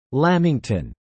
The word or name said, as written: Lamington